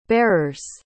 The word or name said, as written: barers